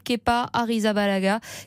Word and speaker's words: Kepa Arrizabalaga: Il sera remplacé à Chelsea par l'Espagnol Kepa Arrizabalaga qui devient le gardien but le plus cher au monde avec un transfert estimé à 80 millions d'euros. – Prononciation impeccable hein, de l'Espagnol Kepa Arrizabalaga.